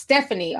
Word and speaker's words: Estefany: By the way, my name is pronounced Stephanie. Oh, sure. Okay. But you had to use the E.